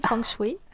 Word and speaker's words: Feng Shui: Déjà, est-ce qu'on le prononce bien ? Est-ce que c'est Feng Shui ou est-ce que c'est autre chose ? – Oui, c'est très bien prononcé, Feng Shui.